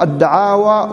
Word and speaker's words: الدعاوى: الآن باب الدعاوى والبينات، تنطق الدعاوى والدعاوي، جمعها دعاوى وهي الادعاء دعوى، الدعاوى والدعاوي، الدعاوي والبينات بكسر الواو وبفتحها. المؤلف